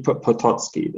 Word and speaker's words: Potocki: So it was probably Potocki then. – Potocki? Oh, okay. Okay. See, I figured I was pronouncing that part wrong.